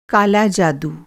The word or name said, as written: काला